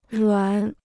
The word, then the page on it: 软: ruǎn